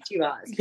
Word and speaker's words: T-Boz: I got to be, it's T-Boz, right? Before I butcher her name, T-Boz. – Yes.